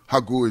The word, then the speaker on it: Haku: Haku is, or Haku, that's the real pronunciation for Hawaiian.